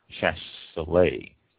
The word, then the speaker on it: Chasselas: That's spelled C-H-A-S-S-E-L-A-S. It's pronounced Chasselet.